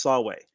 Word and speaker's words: Samhain: Sam is the American name that's shortened for the holiday Samhain, which is spelled Samhain, but it's pronounced Samhain.